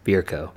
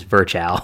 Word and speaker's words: Virchow: I appreciate your proper German pronunciation of Virchow. Not the southern way I learned at med school, which is Virchow.